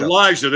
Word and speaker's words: Alijah: That would be Vera Tucker from USC, and I in my intense study of him, I, I, did not get a correct pronunciation of his first name. Elijah. Elijah. – Elijah. – There you go. A L I J A H.